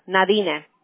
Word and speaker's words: Nadine: So, Edmundo, le voy a mandar ahorita un mensaje de texto que va a llevar mi nombre, Nadine Medina, Nadine. ¿Me puedes decir Nadine? Porque es difícil pronunciarlo, ¿no? En español digo.